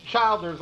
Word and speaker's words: Childers: Do they pronounce it chill or child?" And, and he said, "Chill." Is the correct. All right. So okay, Childers.